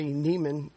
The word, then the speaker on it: Niemann: And his name is Henry Niemann.